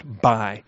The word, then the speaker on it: Mbai: The M is silent. All right, M-B-A-I, but it's pronounced Buy. So think B-U-Y, like we got Best Buy, there's Benny Buy, okay?